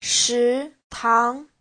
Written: shi2tang2

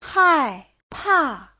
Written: hai4pa4

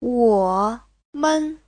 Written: wo3men5

